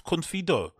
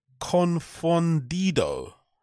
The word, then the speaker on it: confundido: Estoy muy confido.